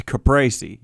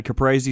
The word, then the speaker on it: caprese: I don't want to get all Italian on him, but it's a caprese. You ever had caprese salad?